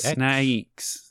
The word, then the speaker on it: Snayx: I think that's how you pronounce it. S-N-A-Y-X. – Snakes.